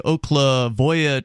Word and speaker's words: Oklevueha: which is the Oklevuia Church, it's spelled O-K-L-E-V-U-E-H-A.